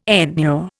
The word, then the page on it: enure: Indian